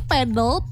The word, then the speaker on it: padel: Nah, selanjutnya kita mau bahas tentang masalah Padel. – Oke. Sebenarnya yang benar itu bacanya padel gitu. Yang benar sih padel.